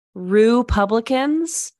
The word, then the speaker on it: Rue Publicans: It's Rue Publicans. So spelled like Republicans, but RU instead of RE in the beginning.